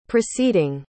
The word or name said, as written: preceeding